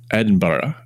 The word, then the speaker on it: Edinburgh: How do I pronounce it? Edinburgh? – Edinburgh. – I will not get that R, but yeah, okay.